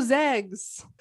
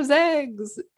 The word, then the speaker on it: Zags: And in the words of Emily Jones, go Zags. – Put it on my tombstone. – Go Zegs. Z-E-G-S. – How do you say it? – Zags. – Go Zags. – Good job.